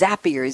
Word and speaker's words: Zapier: The name of the company is Zapier, Z-A-P-I-E-R. It rhymes with happier.